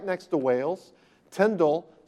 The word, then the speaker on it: Tyndale: And by the way, it's kind of traditional to pronounce his name Tyndale, but he sometimes spelled his name T-I-N-D-A-L-L. So most modern scholars say it should be pronounced to rhyme with spindle.